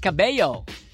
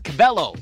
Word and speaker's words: Cabello: Camila Cabello, or Cabello, if you're Spanish. You pronounce it as Cabello. But in the Philippines or in the U.S., Cabello or Cabello.